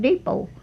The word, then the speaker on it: depot: She said, honey, said, that's called depot. – Oh. – D-O-P-O-T, what? – Yeah. – What? I said, it's depot. – It. She said, honey, the T is silent.